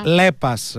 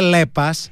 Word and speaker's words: Lepas: Προφέρεται Λέπας. Λε από Λέοπαρτ, από όπου και αντλεί το δυναμισμό, την ευελιξία και την κοψότητά της. Πας από Passion για ανθρώπους με προσωπικότητα και πάθος, για την αισθητική, την τεχνολογία και το καλό, το γούστο.